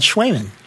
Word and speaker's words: Cwejman: And it's called Schwaemann. – Schwaemann. It's spelled C-W-E-J-M-A-N.